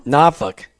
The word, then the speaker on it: Norfolk: You know, on the road today in Norfolk, Virginia, but Martha, why don't, I just said it wrong, Norfolk. That's what they told me I had to say.